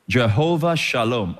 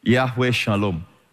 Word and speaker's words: Yahweh Shalom: Jehovah Shalom or Yahweh Shalom. Pronounce it in the Hebrew way. Yahweh Shalom.